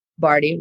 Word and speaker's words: Bardhi: So Pamela Bardhi, Pamela, last name Bardhi, rhymes with party, but not spelled that way.